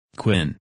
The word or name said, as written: Quinn